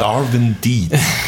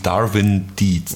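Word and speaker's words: Darwin Deez: Ich weiß nicht, das englische Z spricht man wahrscheinlich eher wie S aus, ne? Aber kleine Empfehlung gleich, auch tolle Kescher-Musik, Darwin Deeds. Darwin wie der berühmte Naturforscher und Deeds, D-E-E-Z.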